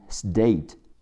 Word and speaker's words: state: 名词州 state，s t a t e state。第一个字母 t。本身发 t，在这儿浊化变成的，the a day stay，最后加上 t 的发音 t，e 在结尾不发音，state state。